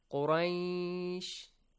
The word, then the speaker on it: Quraysh: Look how I pronounce Quraysh, ay, khawf, ow. This ow sound and ay sound a little longer, two counts.